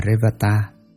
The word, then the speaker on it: Revata: Ngài Li Bà Đa, Ấn Độ đọc là Revata, chữ Hán là Tinh Tú, là bậc không điên đảo rối loạn đệ nhất, tức là ngồi thiền định.